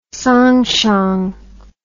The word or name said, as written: siang chiang